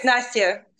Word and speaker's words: Nastiya: I hope I pronounced your name correctly. – Yeah, it's Nastiya. But yeah, Everybody pronounces it differently, to be honest.